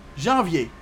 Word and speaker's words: janvier: Janvier, janvier, janvier.